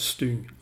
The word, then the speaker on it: stygn: Stygn eller uttalas Styng.